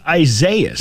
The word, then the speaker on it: Isaias: And I'm like, how do you not know that that's just pronounced Isaias? Why can't you just say that word? – Because he's never actually seen it written down before. – Okay, but somebody tells you it's pronounced Isaias. Okay, fine. I'm pronouncing it Isaiah.